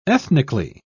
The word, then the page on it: ethnically: British English